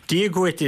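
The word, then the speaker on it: Dia dhuit: Yeah, so you have, Diagwit is in the book, that's thank you, and you helpfully say, rhymes with twit.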